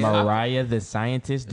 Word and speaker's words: Mariah the Scientist: Maria the Scientist. – She got Mariah. – Mariah the Scientist. Do not disrespect her name ever again.